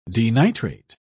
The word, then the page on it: denitrate: British English